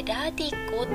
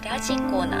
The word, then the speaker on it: radiko: 思うんですけども特に現代ではスマホやタブレットパソコンでラジコというアプリを使って聞いている方多分多いと思います私今までラディコって呼んでいたんですけど正しい発音はラジコなんですね